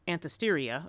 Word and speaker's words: Anthesteria: We might guess that because the rites associated with the Erigone's myth were practiced at the same time of year as the, I'm never going to say this right, Anthesteria, okay, I did say it right, this festival incorporated so many other diverse elements, incorporated her rites too, thereby pulled Erigone into Dionysus' orbit.